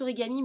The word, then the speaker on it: Origam: Alors, ils prononcent Origami, mais ça se, ça s'écrit Origam.